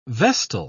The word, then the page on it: vestal: British English